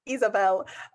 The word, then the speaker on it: Ysabel: And then there's my Instagram, which is India Isabel, but that's spelled I-N-D-I-A and then Y-S-A-B-E-L. It's Isabel, because everyone always gets really confused, and I think that's totally fair enough. It's just a weird spelling, so.